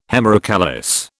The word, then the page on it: hemerocallis: British English